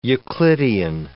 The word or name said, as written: euclidian